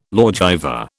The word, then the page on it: lawgiver: British English